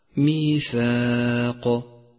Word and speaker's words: ميثاق: ثانيا رققت الألف في كلمة ميثاق ميثاق لأنها مسبوقة بحرف مرقق هو الثاء، فتلفظ هكذا ميثاق.